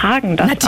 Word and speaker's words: Häagen-Dazs: Hallo, unsere Eiscreme-Marke spricht man Häagen-Dazs aus, ohne den Umlaut, der wird im Skandinavischen nicht mitgesprochen.